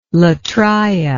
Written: latria

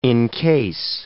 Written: encase

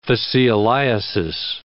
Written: fascioliasis

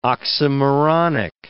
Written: oxymoronic

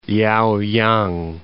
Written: Liaoyang

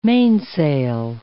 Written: mainsail

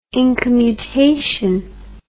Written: incommutation

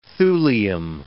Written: thulium

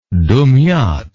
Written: dumyat

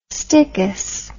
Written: stichous

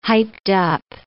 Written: hyped-up

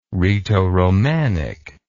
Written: rhaeto romanic

